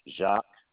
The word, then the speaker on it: Jacques: Jacques, JACQUES.